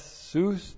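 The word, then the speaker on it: sus (סוס): So behind him, the Lord Jesus Christ, and then we have sus, S long U S, sus. Sus is the Hebrew word for horse.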